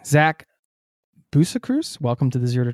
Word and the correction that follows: Busekrus: It's actually Boozy Cruise, a little bit more Booze Cruise esque.